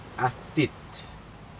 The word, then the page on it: atit: Aneityum